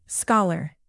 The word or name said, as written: scholar